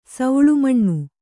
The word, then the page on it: ಸೂಲಮನೆ: Kannada